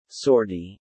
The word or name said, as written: Sortie